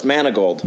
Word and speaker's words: Manegold: Ty, first of all, did I pronounce the last name correctly? – Close. Manegold. – Manegold. Okay. I forgot that little syllable there.